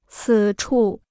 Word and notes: 此处: cǐ chù

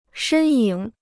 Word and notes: 身影: shēn yǐng

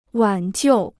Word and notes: 挽救: wǎn jiù

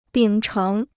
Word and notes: 秉承: bǐng chéng

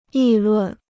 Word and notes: 议论: yì lùn

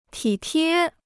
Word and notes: 体贴: tǐ tiē

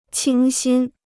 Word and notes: 清新: qīng xīn

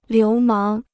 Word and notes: 流氓: liú máng

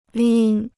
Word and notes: 拎: līn

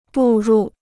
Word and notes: 步入: bù rù

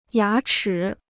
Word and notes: 牙齿: yá chǐ

